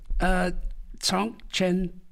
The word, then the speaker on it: Zhong Chen: Now, you both are Zhong Chen practice. Is this the right way I pronounce it? – Exactly that, yeah.